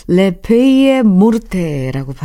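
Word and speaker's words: Les feuilles mortes: 이브 몽땅이 부른 고엽 네, 이게 레페이의 모르테라고 발음을 하네요.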